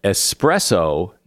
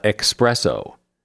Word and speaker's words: espresso: Strong coffee in a tiny cup has no X in it, either in writing or pronunciation. It is espresso, not expresso.